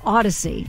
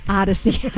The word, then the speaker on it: Odyssey: Do an AI version of the movie Odyssey. No, Odyssey. I'm sorry. It's not our company. It's Odyssey. I say it wrong every time.